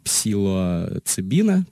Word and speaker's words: псилоцибин: Первая тема у нас, как я уже сказал, это микродозинг э, разных э, психоделических разных препаратов, в том числе ЛСД и псилоцибина, правильно сказал, да? Да, псилоцибин.